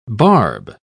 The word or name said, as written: barb